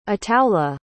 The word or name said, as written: ataullah